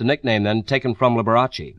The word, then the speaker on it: Liberace: In Italian, you roll the R a little bit. You say Liberace. Liberace.